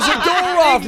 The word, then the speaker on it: Zadorov: Oh, Zadorov, Nikita, doggone it. – Wait, yeah. – Wait, Emily Wade in the audience says his name rhymes with Dadorov.